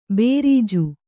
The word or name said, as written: ಭರ್ಜಿ